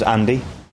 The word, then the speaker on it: Andy: My name's Andy. – How do you spell it? – A-N-D-Y.